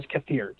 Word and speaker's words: kefir: You can pronounce it that way. Some people pronounce it kefir. Snobs like me will pronounce it as kefir.